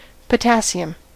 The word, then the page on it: potassium: American English; /pɵˈtæsiəm/